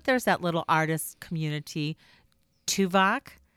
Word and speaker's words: Tubac: Which is spelled T-U-B-A-C. – He's on Voyager. He's the Vulcan on Voyager. – No, but it's actually a place, but that's pronounced with like a V instead of a B.